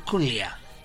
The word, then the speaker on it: Culea: Como como Carla Sibila es profesora de inglés, me dice, ¿cómo se pronuncia? Me dijo, Culea.